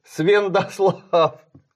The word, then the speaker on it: Святослав: До этого Юс мало читался как Н. То есть он был Свендослав.